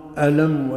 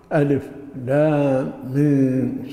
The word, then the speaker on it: الم: ولا يقال ألم ولا ألم ولا ألم صاد، لابد أن تنطق بالأصوات العربية بأصواتها المعروفة، ألف لام ميم صاد ألف لام راء طه، وهكذا.